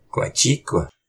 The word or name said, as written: Qaciq